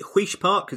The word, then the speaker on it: Huish Park: So they sold, is it Huish Park? Is that how you pronounce it? – Yes.